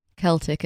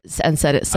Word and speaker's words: Celtic: And it turns out that Hussein, friend of the pod, doesn't know that Celtic is pronounced with a hard C. and said it Celtic. – Oh, because the football club is pronounced Celtic, so that's an easy mistake to make.